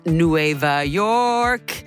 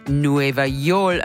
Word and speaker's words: Nueva York: And I've been learning the cities, and it's Nueva York is how you actually pronounce it, according to Duolingo. Anyway, then I discovered that that's the way Puerto Ricans pronounce New York, as in Nueva Yol and not Nueva York, like other Spanish-speaking countries.